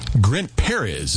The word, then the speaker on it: Grant Perez: Grant Perez is how you pronounce the name of that group, Absence of You.